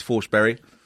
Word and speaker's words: Forsberg: In case you didn't know, that's how you pronounce his name properly. It's Forsberry.